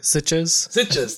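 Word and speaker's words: Sitges: Recently won Best Director at the Sitka Genre Fest. If I'm pronouncing that right, I think. – Sitches. – Thank you. Yep.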